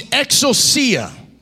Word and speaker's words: exousia: That word power there in the Greek means exousia. That's how you say it in the Greek, I think.